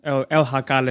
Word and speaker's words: El Jacal: And so she's got another El Jacal, is how you pronounce it, but it's spelled El Jackal.